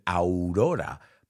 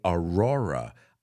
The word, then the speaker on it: Aurora: Now, you guys pronounce it Aurora, but we say Aurora.